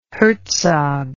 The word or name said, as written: Herzog